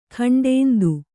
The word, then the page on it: ಖಂಡೇಂದು: khaṇḍēndu